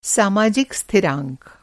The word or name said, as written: स्थिरांक